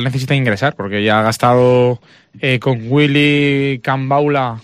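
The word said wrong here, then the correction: Kambwala: El nombre, Dani, tú que estuviste en la presentación, ¿es Cambaula, Cambala? – Así mismo se llama Kamwala.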